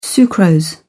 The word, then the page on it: sucrose: British English